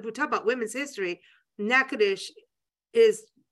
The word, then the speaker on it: Natchitoches: And I'm going to spell it, and it's Natchitoches, not Natchitoches, which I would have said for years, like, you know, Nancy. But it's Natchitoches.